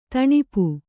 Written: ದಣಪೆ

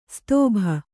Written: ಸ್ತೋಭ

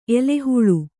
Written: ಎಲೆಹುಲಿ

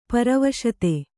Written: ಪರವಶತೆ

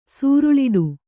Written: ಸೂರಳಿಡು

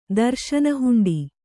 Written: ದರ್ಶನಹುಂಡಿ

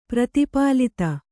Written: ಪ್ರತಿಪಾಲಿತ

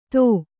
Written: ಥೂ

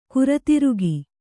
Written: ಗಿರಿದುರ್ಗ

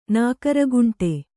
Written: ನಾಗರಖಂಡ

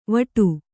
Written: ವಡ

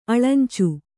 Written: ಅಳಂಚು

